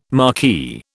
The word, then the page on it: marquee: British English